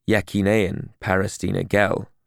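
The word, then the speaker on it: Yekîneyên Parastina Gel: The YPJ, or Yekineyen Parastina Jin, pronounced Yekineyen Parastina Jin for women, and the YPG, or Yekineyen Parastina Gel, pronounced Yekineyen Parastina Gel for men.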